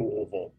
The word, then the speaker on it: Louisville: and then the last one is L O L U like L O O V U H L L like Louisville.